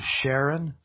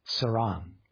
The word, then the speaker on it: Sharon: And the plain is not the plain of Sharon. Sorry, Sharon. It's the plain of pronounced Saran. There's no H.